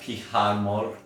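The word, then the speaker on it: Pyjarmour: I think that's pronounced Pihamor.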